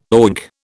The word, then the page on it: oink: British English